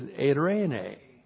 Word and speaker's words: eirene: And the Greek equivalent to shalom is eirene. And she's sitting right down here. E-I-R, long E, N, long E. We get Irene from Irene.